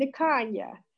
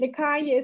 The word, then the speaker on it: Nikonya: Minister, where Ashley is MIN, which is for minister, Tara, T-E-R-A, and my middle name, Nikanya. And Nikanya is spelled N-I-K-O-N-Y-A, Minister Tara Nikanya.